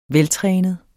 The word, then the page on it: veltrænet: [ -ˌtʁεːnəð ]; [ -ˌtʁεˀnəð ]